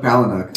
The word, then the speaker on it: Palahniuk: I was about to ask how you pronounce his last name. I've never heard anyone say it. – Chuck Palahniuk.